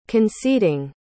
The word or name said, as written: conceding